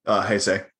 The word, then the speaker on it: Heisei: Heisei.